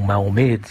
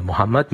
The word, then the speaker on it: Mohammed: Non, je crois que, bon, si vous voulez, la prononciation Mahomet vient d'un passé assez chargé de polémiques et d'attaques contre l'islam et contre son prophète, qui était considéré pendant tout le Moyen-Âge comme un faux prophète et un charlatan. Mais maintenant, bon, le mot Mahomet, disons, la forme francisée un peu neutre de ce personnage du 6e, 7e siècle. Alors, la prononciation arabe est bien sûr Mohammed, mais je pense pense qu'en disant Mahomet, les Français et les francophones comprendront beaucoup plus facilement de qui on parle.